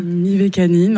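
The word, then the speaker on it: Mivekannin: Mivacanin ou Mivacanim? – Mivacanin, en tout cas c'est comme ça que moi je le prononce.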